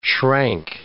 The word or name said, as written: shrank